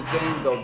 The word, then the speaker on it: Django: Goddamn, I'ma do this shit just like I'm the Django. The D is silent, hell no, she get it wild.